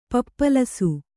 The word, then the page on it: ಪಪ್ಪಲಸು: pappalasu; Kannada